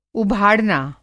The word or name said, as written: उभाड़ना